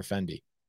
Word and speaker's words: Fendi: Is it Fenty or Fendi? – Yeah. Yeah. Fendi, like all the designer stuff.